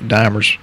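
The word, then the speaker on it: D-dimer: To help the D dimer, and I've heard it pronounced dimmer, but I think dimers.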